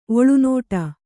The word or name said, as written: ಒಳನಾಡು